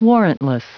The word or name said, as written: warrantless